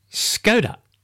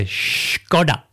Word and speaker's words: Škoda: The reason they did this was because Škoda is often mispronounced as Skoda.